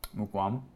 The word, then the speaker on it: Mukuamu: Um, I thought it was Muwakuomo. How do you say it? – Mukuamu. – Mukuamu, dang it.